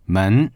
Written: men2

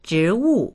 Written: zhi2wu4

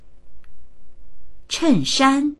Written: chen4shan1

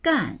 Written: gan4

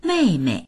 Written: mei4mei

